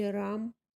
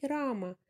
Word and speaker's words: Рама: Естественно, они должны говорить Рама, не Рам, поскольку так будет правильно.